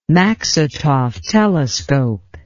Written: maksutov telescope

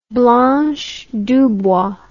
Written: Blanche DuBois

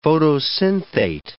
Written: photosynthate

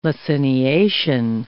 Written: laciniation

